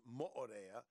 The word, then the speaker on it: Mo'orea: If you get lost on Mo'orea, properly pronounced Mo'orea, you really shouldn't be out in public on your own.